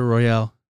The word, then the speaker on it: Royale: It's not Battle Royale, it's Battle Royale. – Oh, my bad. – There's an E at the end. I don't know what the significance of the E is. – Battle Royale. – It's pronounced Royale. – It's a Battle Royale. Because then that would just be like R-O-Y-A-L-L-Y.